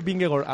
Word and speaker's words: Vingegaard: Que, por cierto, ayer dije Vingegaard a las 8:30 y casi me muero de la risa yo solo, por pronunciarlo bien. Es Vingegaard el que viste el maillot, pero es propiedad, y el premio sería para él, para Tadej Pogačar, que es muy joven.